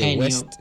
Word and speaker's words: Kanye West: Kanye West. Kanye, West. – Kanye, El marido de Kim Kardashian.